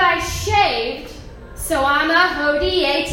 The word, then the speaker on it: hodie: So how to say yesterday in Latin is hairy, H-E-R-I, long I. And how you say today in Latin is hodie. You can say odie, but I'm going to say hodie for the sake of this joke, but it's spelled H-O-D-I-E.